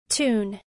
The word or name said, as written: tune